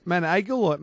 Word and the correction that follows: Montagil: or how do you pronounce this one? – Montagil. – Montagil.